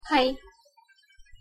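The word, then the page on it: hai: Malay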